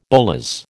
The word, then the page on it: bolas: American English